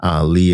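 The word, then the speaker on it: Li: Lee is spelled L-I, so that's Dr. William L-I, Lee, M-D.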